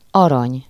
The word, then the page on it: gold: English